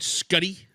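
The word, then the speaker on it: Schutte: All right, you listeners out there, you're going to say to yourself, how do you spell Scutty? Yeah, I had to phonetically lay it out, but it's S-C-H-U-T-T-E. – That is correct.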